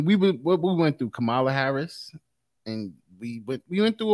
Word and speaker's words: Kamala: You better say it right. – Carmela Harris. – No. – That's not her name. – I can never pronounce her name. – Kamala.